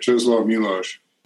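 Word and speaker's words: Czesław Miłosz: Yes, right, Czeslaw Milosz.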